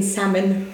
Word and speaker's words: salmon: So they pronounce the L in salmon.